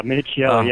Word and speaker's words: Minichiello: And these personal, you know, grievances could play out, you know, I guess in the best case scenario, like it did for Raphael Minichiello, is that how you say his name? – Yeah, Minichiello.